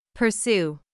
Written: pursue

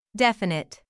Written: definite

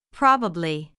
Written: probably

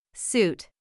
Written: suit

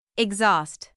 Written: exhaust

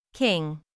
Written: king

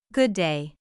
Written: good day

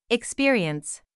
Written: experience

